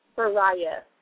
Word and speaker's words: Souraya: Souraya.